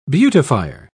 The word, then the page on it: beautifier: British English